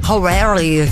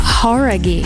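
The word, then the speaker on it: Jauregui: Lauren Horaghi. Talvez você já tenha ouvido falar dela, ex-integrante do grupo Fifth Harmony. – Horaghi. Como é? – Horaghi. – Horaghi. Ah, então a gente tava falando errado, cara.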